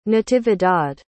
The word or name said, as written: natividad